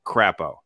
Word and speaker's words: Crapo: But armed, you know, and so Idaho and Mike Crapo in the Senate, perfect name from a guy from Idaho, because his ideas are going to be crap, but also rhyme with the name of the state, Crapo.